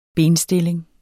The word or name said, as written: benstilling